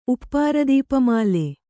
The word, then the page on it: ಉಪ್ಪಾರದೀಪಮಾಲೆ: Kannada